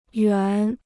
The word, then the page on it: 园: yuán